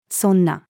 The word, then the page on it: そんな: female